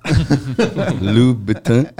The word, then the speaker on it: Louboutin: Louboutin.